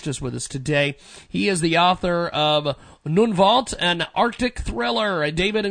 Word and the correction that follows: Nunavut: Uh, Nunavut is the name of the self-governing territory in Canada, which the Canadians have uh, given to the Inuit people.